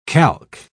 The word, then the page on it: calque: British English